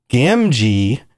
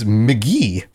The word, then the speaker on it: Gamgee: Well, Sam Gamgee, in my middle school brain, or I guess fifth grade brain, was McGee for some reason.